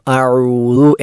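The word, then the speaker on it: അ ഊദു (أعوذ): അ ഊദു എന്നാണ് ശരിയായ ഉച്ചാരണം, ബാക്കിയെല്ലാം തെറ്റാണ്.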